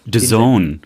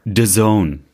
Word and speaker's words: DAZN: DAZN. – DAZN. Nochmal bitte für alle. – DAZN.